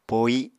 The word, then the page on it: pohi: Apatani